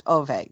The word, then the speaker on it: Ove: I was saying Ove, but it's pronounced Ove in Swedish.